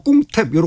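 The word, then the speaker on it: 꿍탭 (กรุงเทพ): 요 사람들은 이제 겹자음이 올 때 거의 그냥 동시에 발음을 하죠. 그래서 크롱이라고도 안 그러고 꿍탭 이렇게 발음하는 맞습니다.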